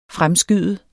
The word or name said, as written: fremskyde